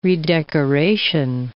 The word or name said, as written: redecoration